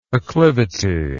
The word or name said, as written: acclivous